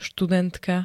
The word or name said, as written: studentka